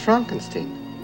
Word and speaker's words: Frankenstein: No, it's pronounced Frankenstein.